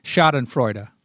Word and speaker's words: Schadenfreude: And the Schadenfreude, how do you say the German word that means like you take pleasure in other people's misery? – Schadenfreude. – It's something like Schadenfreude.